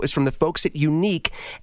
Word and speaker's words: Yuneec: The company is called Unique. I love the way they spell it. Y-U-N-E-E-C, Unique.